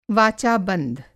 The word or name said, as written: वाचा-बंध